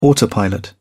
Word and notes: autopilot: British English

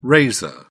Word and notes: raiser: British English

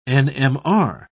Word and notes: nmr: British English

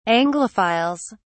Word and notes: anglophiles: British English

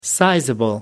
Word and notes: sizeable: American English

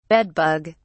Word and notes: bed bug: British English

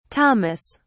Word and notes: thomas: British English

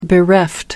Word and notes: bereft: American English

